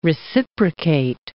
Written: reciprocate

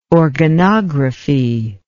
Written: organography

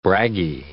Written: braggy

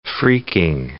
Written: phreaking

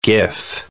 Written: GIF

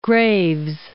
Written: Graves

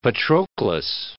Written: Patroclus

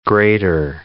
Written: Greater

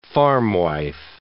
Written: farmwife

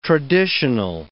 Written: traditional

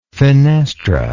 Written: finestra

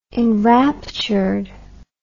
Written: enraptured

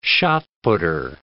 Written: shot-putter

